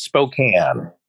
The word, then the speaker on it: Spokane: It's pronounced Spokane. Spokane. – Spokane. – Spokane. – Spokane. – It spoke like a bike. – It spoke like a bike, and then there's a can in that.